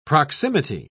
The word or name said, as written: proximity